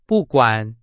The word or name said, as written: 不管